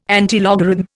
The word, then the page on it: antilogarithm: Indian